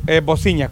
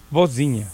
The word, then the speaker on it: Vozinha: Sí, Bociña, con V. Bociña, pues sí, ¿sabes cómo se escribe? – No. – V-O-Z-I-N-H-A. Bociña. – Z-I-Latina. – Sí. Z-I-N-H-A. Bociña.